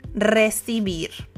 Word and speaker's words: recibir: And we're going to take the verb receive, recibir. Recibir. Yes. It's spelled only when one R in the beginning, but if the R is in the beginning, you have to roll your R.